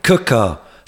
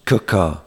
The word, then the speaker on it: Quooker: This podcast is sponsored by Cooker, spelt Quooker, but pronounced Cooker. This amazing painted, spelt Quooker, by the way, Q-U-O-O-K-E-R.